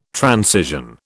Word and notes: transition: British English

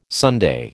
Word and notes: Sunday: American English

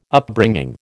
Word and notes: Upbringing: American English

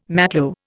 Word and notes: matlo: Indian

